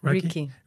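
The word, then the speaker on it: Reiki: Riki? Ruki? – Riki.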